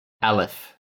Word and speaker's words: Aleph: There's about 1,600 members as of 2011, and they're known under the name Aleph. – Yes. – A-L-E-P-H. – Yeah. I think that's how you pronounce it as well.